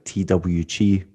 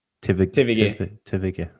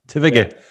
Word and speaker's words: TVG: See, that's where the Austrian comes in, because I would always pronounce it like TWG, but you're like... – Everyone. – Tivige. Right, okay. Tivige.